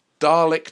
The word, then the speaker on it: Dalek: It's Dalek to rhyme with Bra-lick.